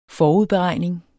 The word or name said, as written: forudberegning